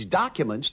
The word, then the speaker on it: documents: The O of documents is pronounced with the sound of the A in arm. – If you want a work permit, you'll have to take these documents to the city hall.